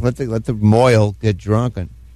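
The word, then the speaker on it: mohel: I don't like the name Moyle. It rhymes with boil.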